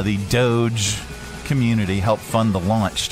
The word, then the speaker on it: Doge: We are looking at how to pronounce the name of this cryptocurrency. How do you say it? Yes, it is said as Doge. – Doge. – Doge. Doge. Therefore, Dogecoin.